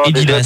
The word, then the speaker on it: Adidas: Et également, alors je sais pas comment il faut prononcer, Adidas, Asdedi, Didadadas, Edidas. – Non, Adidas, t'as tout faux, mon grand.